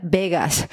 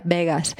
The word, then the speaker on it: Vaguess: Pues está el caso de The Bagues, que he visto por ahí que se pronuncia Vegas, pero bueno, da igual, o sea, para que hagáis una idea de cómo se escribe, es V-A-G-U-E-S-S.